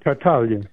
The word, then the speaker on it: Tartaglia: The Last Gift of the Magi by Louis Tartaglia. Is that Tartaglia? – Right. – Tartaglia. Is that it? – Tartaglia. – Tartaglia. Okay, the G's not pronounced.